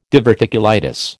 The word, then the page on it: diverticulitis: American English